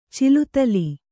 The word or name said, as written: ಚಿಲುತಲಿ